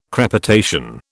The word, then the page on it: crepitation: British English